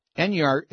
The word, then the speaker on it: Enyart: Enyart is spelled E-N-Y-A-R-T.